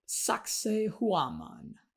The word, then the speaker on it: Sacsayhuaman: Moving on to Sacsayhuaman. I'm trying to pronounce that in a Peruvian way.